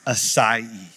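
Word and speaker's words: açaí: And I said, it's pronounced acai.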